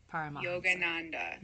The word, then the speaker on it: Yogananda: Yogananda.